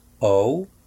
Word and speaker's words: owe: I owe a little money. Debo. I owe un poco de a little dinero, money. I owe a little money. La letra E en la palabra owe no se pronuncia.